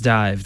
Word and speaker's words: DIIV: The name of the band is Dive. It's pronounced Dive. They spell it differently, though. D-I-I-V.